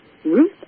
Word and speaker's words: Ruth: J-A-N-I-N-E. Burnham looks more like Burnham. B-U-R-N-H-A-M. Ruth, R-U-T-H, like the babe, .com.